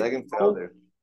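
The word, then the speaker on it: Längenfelder: É, é, como é que é pronuncia o nome dele? Eu não sei pronunciar o nome dele. Ó, Ken Roxen, Simon Legenf Legenfelder. Legenfelder. E é, esse... Legenfelder. – É, é não sei se pronuncia assim, mas enfim, é Sam, Simon Legenfelder.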